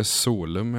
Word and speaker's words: Solum: Alltså, och hur uttalas det? – Eh, jag säger Solum i alla fall, men mm.